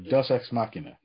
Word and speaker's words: deus ex machina: Deus ex machina, yeah.